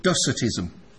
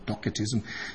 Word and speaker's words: Docetism: Technically, it's called Docetism, for those who, or Docetism, depending on which pronunciation you want.